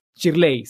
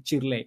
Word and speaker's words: Shirley: Muchos, muchos, muchos, muchos, muchos saludos para ti, Shirley Serrano Guerrero. Muchos, muchos saludos. Chirley, sí, Chirley. Muchos, muchos saludos.